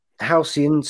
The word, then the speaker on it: Halcyon: I hope I've said that right. – Halcyon Star Cruiser. Thank you so much.